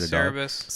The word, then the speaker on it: Cerberus: Yeah, Cerberus.